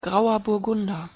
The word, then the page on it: grauer burgunder: German